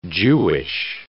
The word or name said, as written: Jewish